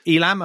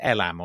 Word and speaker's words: Elam: So Elam, are you going to pronounce it Elam or something? – No, Elam's good. Yeah.